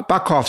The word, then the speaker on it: Bochkov: His name's Val Bakhov. I'm sorry if I'm saying that so badly. – Bakhov sounds correctly. It's spelled B-O-C-H-K-O-V.